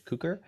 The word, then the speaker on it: Cukor: We're excited to discuss the film Born Yesterday, the 1950 version, directed by George Cukor.